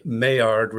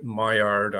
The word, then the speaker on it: Maillard: Jaspis is J-O-S-P-I-C-E. Also, I mispronounced a couple of words, few words, French, how it is. The Maillard reaction, I should have said, I called it Maillard, I'm sorry, for the image formation.